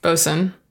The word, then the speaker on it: boatswain: And but how do you pronounce boatswain again? – Bosun.